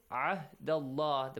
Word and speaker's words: عهد الله: The next one is عهد الله. The pronunciation of the letter عينن عهد الله. And how you pronounce the letter لام and the word Allah.